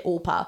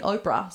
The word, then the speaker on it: Orpah: So Orpah was actually named after a biblical figure in the Book of Ruth, and she got pronounced so many times wrong. People just couldn't get Orpah. They just kept saying Oprah.